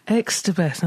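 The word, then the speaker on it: Xstabeth: is it Extabeth, I've been saying, in a faintly Mexican way. – Extabeth.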